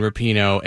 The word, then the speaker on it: Rapinoe: It's pronounced Rapinoe.